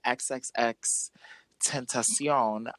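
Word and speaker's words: XXXTentacion: And he actually dissed XXX Tentacion, and his mom was in the audience. That is how you say his name.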